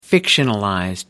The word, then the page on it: fictionalized: British English